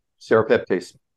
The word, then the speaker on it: serrapeptase: Serrapeptase.